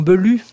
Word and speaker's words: Bellew: Mais par exemple, quand Bellew, dans le roman Bellew, La fumée, quand Bellew décide d'écrire, Bellew, c'est le nom Bellew, mais français, prononcé à l'américaine, comme ils ne savent pas prononcer les U, ils disent Bellew.